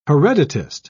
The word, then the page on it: hereditist: British English